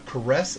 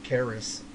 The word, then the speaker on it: Caress: I did one with you already, the one album before this, Caress of Steel, not Caris.